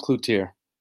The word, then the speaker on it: Cloutier: Well, it's pronounced Cloutier.